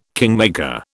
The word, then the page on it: kingmaker: British English